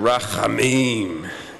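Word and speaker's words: rachamim (רחמים): And that H is a hard H that you got to kind of scrape your throat like you're German or something. So rachamim.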